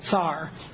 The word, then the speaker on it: Tsar: But it's pronounced like that, like she would have pronounced it, Tsar.